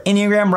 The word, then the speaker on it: Enneagram: So long story short, Enneagram rhymes with pentagram.